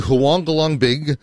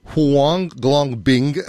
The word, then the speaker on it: Huanglongbing: Do Huanglong Big... Huanglongbing.